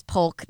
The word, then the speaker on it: Polk: You know, oh, we're just Polk County, which it's Polk. There's an L in there. I'll always continue to fight for people to pronounce the L. Don't drop the L.